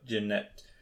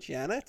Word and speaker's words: Janet: Janet? – Janet? I said Jeanette. That's a way of pronouncing it, maybe. Played by Elizabeth... – It's literally spelled Janet.